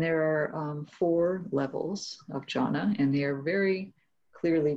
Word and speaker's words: jhana: But there are also some distinct states of strong samadhi that are called jhana, and that's J-H long A-N-A. And they are also, it's translated into English, it's sometimes translated as absorption or immersion, or it's just left untranslated as jhana.